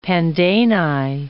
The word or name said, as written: pandani